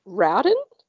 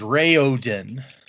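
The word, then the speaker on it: Raoden: Okay, on his website, he has a pronunciation guide, so let's see what it says. – Okay. – It is Rayodin.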